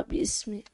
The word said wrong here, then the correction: باسم: مش باسمي يا ولد. مفيش ألف هنا.